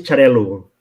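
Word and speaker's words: Xarel·lo: In Spanish is Charello in Spanish. In Catalan is Charello. It's quite similar, but finishing O or in U is the big difference between in Catalan and Spanish, but it's the same.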